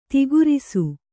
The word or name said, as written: ಥೂಕರಿಸು